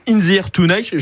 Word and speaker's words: In the Air Tonight: le Doubs avec Dampierre-les-Bois et puis In the Air Tonight. Je l'ai bien prononcé, Sylvie, vous êtes d'accord? – Très bien.